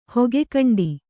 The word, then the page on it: ಹೊಗೆಕಂಡಿ: Kannada